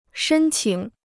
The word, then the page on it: 申请: shēn qǐng